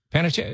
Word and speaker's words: Panettiere: You know, I'm listening right now to the Hayden Panettiere. – Yeah. – Audiobook. Did I say it right? – Panettiere. Yeah. I say Hayden Panettiere.